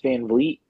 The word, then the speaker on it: Van Vliet: I think she was on Chris Van Vliet. Is that how you say his name? – Yeah. Yes.